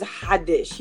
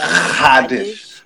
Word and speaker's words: Haddish: Yeah, the way you pronounce it is Haddish. You just kind of use your throat and go Haddish.